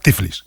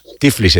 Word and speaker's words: Tiflis: Nos escucha Héctor desde Georgia, desde Tiflis, la capital, o Tbilisi, no sé cómo se dice ahora. – Castellano, Tiflis. – Tiflis se dice, vale.